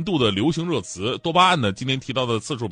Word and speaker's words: 多巴胺: 啊，多巴胺，因为很多人呢都习惯性把它读成多巴胺啊，呃，你们是不是觉得，哎呀，这也没什么，我早就知道了哈？